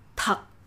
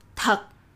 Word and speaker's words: thật: For example, if you see the word in its written form before hearing it, your brain will tell you that that it's pronounced that, like in English, while in fact it is pronounced thật, thật. So this listen first read later approach is designed to avoid that interference.